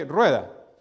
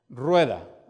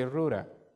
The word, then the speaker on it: Rueda: Rueda. You know, like, the R in Spanish is so complicated. The brrr, little twist in there, like, it's like some letters in English I can get, right?